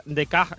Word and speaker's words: Descartes: Porque como te he dicho, Descartes se pronuncia Descartes en francés, ¿vale?